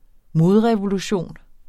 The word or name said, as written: modrevolution